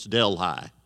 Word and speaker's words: Delhi: He lived out in western Oklahoma, a little place called Delhi, spelled the same way as Delhi in India, but in Oklahoma, it's Delhi.